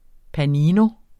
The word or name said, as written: panini